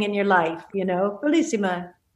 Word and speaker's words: Bellissima: So Bellissima, I'm saying that right? Yeah. Right? Yes.